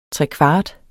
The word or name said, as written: trekvart